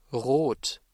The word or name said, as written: rot